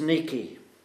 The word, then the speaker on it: Nike: Nike, as is pronounced in Greek.